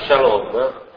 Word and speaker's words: shalom: Per esempio la parola shalom è fatta di 2 sillabe, sha e lom. L'accento cade su lom,